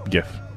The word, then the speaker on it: GIF: But it's still pronounced GIF.